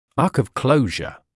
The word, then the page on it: arc of closure: ɑːk ɔv ‘kləuʒə